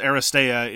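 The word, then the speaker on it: Aristeia: It's Aristia. Aristia? Aristia? See, this way I don't say these. – I believe it's pronounced Aristea. It's Greek in origin, but I don't know much about Greek pronunciation. – Aristea.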